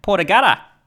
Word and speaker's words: Port Augusta: Now, Port Augusta in Australian vernacular is pronounced Port Augusta, mate. Port Augusta.